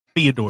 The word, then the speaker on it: Fyodor: Fyodor.